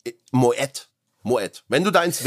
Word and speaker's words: Moët: Marc, wie spricht man Moe Chandon richtig aus? – Moe Chandon. Falsch. – Okay, Moet zack. – Chandon. – Moet. Ah, okay, tatsächlich, ja? – Tatsächlich.